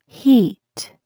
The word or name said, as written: heat